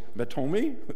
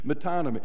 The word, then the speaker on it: metonymy: I'm not even sure if I can get the emphasis on the right syllable, but it's a metonymy, is that it? Or metomi? Metonymy.